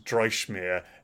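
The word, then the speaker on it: Dreichmer: I believe it's pronounced Dreischmeer. It's D R E I C H M E R, and this is a band, a one-man pros- project of uh, Dustin Matthews.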